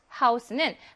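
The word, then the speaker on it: Rathaus: 자, Rathaus는 합성어입니다. 자, 여기는 자, 복자음 태하가 쓰인 게 아니고요. 이렇게 끊어져서 가는 거예요.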